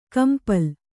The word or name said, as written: ಕಂಪಳ